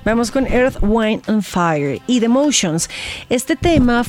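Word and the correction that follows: Earth, Wind & Fire: ¿Te gustó la pronunciación o no tanto? – Wind and Fire. Perfecto. Tienes, la pasa 3 veces por programa al grupo. Nico, su opinión. – Earth, Wind and Fire, hombre.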